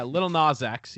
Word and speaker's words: Lil Nas X: So the song Old Town Road, Lil Nas X. Am I saying that right? I've never said it out loud. – Yeah, Lil Nas X.